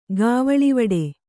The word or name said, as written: ಗಾವಳಿವಡೆ